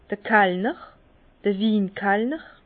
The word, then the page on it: d'r Kallner, d'r Win-Kallner: Bas Rhin; Herrlisheim